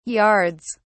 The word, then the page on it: yards: British English